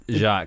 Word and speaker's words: Jacques: Jacques. – Jacques has an S at the end, eh? – Yeah. – Yeah, but you just say Jacques, right? – Wow.